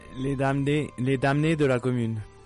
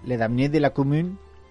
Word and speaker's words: Les Damnés de la Commune: No sé si lo he dicho bien, lo he dicho fatal. – Más o menos, bueno, para que lo entiendan los oyentes, bien. – Bien. – Bueno, ¿cómo se dice? A ver, que ahora lo voy a empezar yo a decir y luego reír de mi pronunciación. – Les Damnés de la Commune.